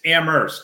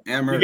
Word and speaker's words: Amherst: It's Amherst. Don't pronounce the H. You got to be a local. All right, Amherst.